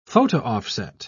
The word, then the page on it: photo-offset: British English